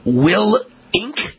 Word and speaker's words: Willink: Hey, Jocko Willink.